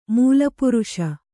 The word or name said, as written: ಮೂಲಪುರುಷ